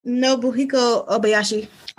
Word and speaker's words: Nobuhiko Obayashi: Nobuhiko Obayashi.